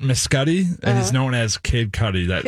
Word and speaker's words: Kid Cudi: How do I say his name? – Kid Cudi. – Kid Cudi. Cudi, like Cudi Sark.